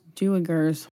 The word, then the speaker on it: duikers: It's D-U-I-K-E-R-S. – It's pronounced Duikers.